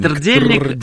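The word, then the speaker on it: trdelník: Я не понимаю тогда, как это произносить. – Трдельник. – Первая буква какая? – Т. Трдельник. А слово трдло.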